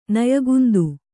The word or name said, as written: ನಯಗುಂದು